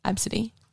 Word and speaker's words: Abcde: Especially when so many fucking white people are making up these ridiculous fucking names like Absidy, which is A-B-C-D-E, Absidy.